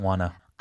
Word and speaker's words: wanna: I didn't wanna. – I didn't wanna. 그러니까 want to를 이어 발음하게 되면 want to인데 조금 더 빠르게 발음하면 wanna.